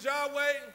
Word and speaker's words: Yahweh: Sometimes we pronounce it as Yahweh. Sometimes it would be pronounced as Jehovah.